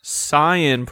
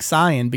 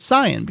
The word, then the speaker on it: Saiyan: But whenever they've done some reviews that include pronunciations like Saiyan, because you look at the word and you say, how would I pronounce this? Of course, I would pronounce it Cyan because of the A and the I next to each other.